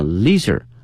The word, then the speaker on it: leisure: 哎，这里面悠闲的生活啊，我们可以用这个 leisure 这个词 L-E-I-S-U-R-E 表示悠闲的这个词啊，有两个发音，一个呢是 leisure 啊 leisure，另外一个发音呢是 leisure 都可以，但是呢作为形容词的话呢，后面加一个 ly 啊 leisurely life， leisurely life 表示过于悠闲的哈，一天没有什么事儿可干哈，那么这种生活方式叫 leisurely life。